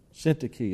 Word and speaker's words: Syntyche: I beseech Euodias and beseech Syntyche is how that's pronounced, that they be of the same mind in the Lord.